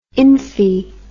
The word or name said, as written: imphee